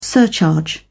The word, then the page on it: surcharge: British English